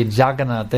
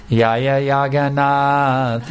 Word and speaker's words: Jagannath: Just like I have seen devotees in Mexico, they don't say the letter J, just like we say Jagannath. They don't have that letter J in their alphabet. So when they were doing the kirtan, instead of going Jaya Jagannath, they were going Yaya Jagannath, Yaya Jagannath, Yaya Jagannath, Yaya Jagannath.